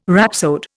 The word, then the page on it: rhapsode: Indian